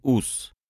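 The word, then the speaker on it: Ouse: Сейчас это название произносится «ус», но в Старой Англии оно рифмовалось со словом «маус».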